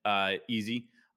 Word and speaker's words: Eze: Nothing incredible on this game other than Eze, I believe is how you pronounce it, right? – Yeah.